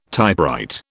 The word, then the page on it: typewrite: British English